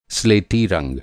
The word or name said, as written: स्लेटी